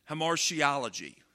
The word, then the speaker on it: hamartiology: And that big word there is pronounced hamartiology. Yeah, there's one to go home and... What'd you learn about tonight? We learned about hamartiology.